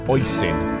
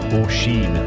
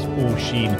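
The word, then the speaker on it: Oisín: They recently visited Ireland after the lockdown and were mortified when the grandparents turned around and said it's actually pronounced Oisin. Oisin, Oisin! And it's actually pronounced Oisin.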